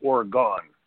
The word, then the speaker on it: Oregon: I was in it's spelled Oregon, but you get corrected very quickly. It's Oregon, Ohio, and it's right on the outskirts of Toledo.